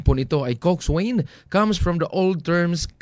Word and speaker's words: coxswain: So, did you know that Coxswain, pronounced as Coxswain, mm-hmm, mga kapwersa?